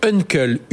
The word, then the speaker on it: Unkle: On parle de néo-assurance avec Uncle, U-N-K-L-E. Voilà, ça se, ça se prononce bien.